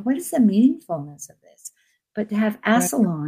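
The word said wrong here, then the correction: Alcyone: right. Am I saying that right? I have trouble with Alcyon. – Alcyon. Yeah, right.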